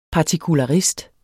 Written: partikularist

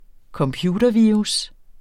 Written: computervirus

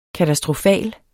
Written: katastrofal

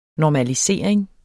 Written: normalisering

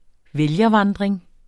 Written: vælgervandring

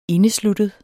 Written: indesluttet

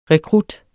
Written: rekrut

